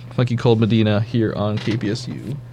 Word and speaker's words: Funky Cold Medina: Well, this is the funky Comadina, if you hadn't figured that out. Did I say that right? Comadina? – Funky Cold Medina.